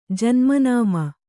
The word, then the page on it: ಜನ್ಮನಾಮ: Kannada; janma nāma